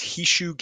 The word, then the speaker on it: Hisshou: Hisushi stream. – Hishu, I believe it's Hishu Gaming. HISSHOU Gaming on Twitch.